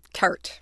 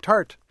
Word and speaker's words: Tarte: I pulled from the bottom of the stack again, and there's a book called Foul Weather by, how do you pronounce that? – Tart. – Tart. – Yes. – Tart, T-A-R-T-E, author of Enslaved by Ducks.